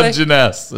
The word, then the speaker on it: Dungeness: Well, and these are dungarees crab? How do you say dungarees? – Dungarees. What did I say? – Dungeness is what.